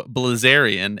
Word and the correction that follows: Bilzerian: In fact, it's Bilzerian, I-L, not L-I.